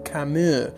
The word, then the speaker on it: Camus: Obviamente el Camus no se pronuncia Camus, se pronuncia Camus. Porque él es francés y los franceses son así.